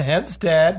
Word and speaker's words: Hempstead: All right, I'm here with Katelyn Hempstead.